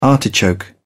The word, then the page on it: artichoke: British English